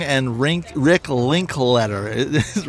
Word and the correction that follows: Linklater: right? – Linklater. – Linklater. Yeah.